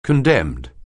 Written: condemned

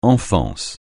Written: enfance

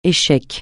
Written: échec

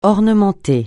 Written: ornementer

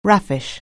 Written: raffish

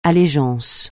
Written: allégeance

